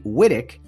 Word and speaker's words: Witek: And the last name is spelled W-I-T-E-K. Not quite like Vic Winnick, but Frank P. Wittick is is the person we're going to talk today about in our Suds with Studs segment.